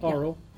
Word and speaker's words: Auril: Need to let the Order of the Gauntlet know that Aurel has been seen up north. Frostmaiden. – Aurel? – Aurel? Isn't that how it's said? – Aurel. Yeah. A-U-R-I-L.